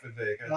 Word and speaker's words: Vivek: Vivek rhymes with cake.